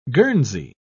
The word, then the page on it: guernsey: British English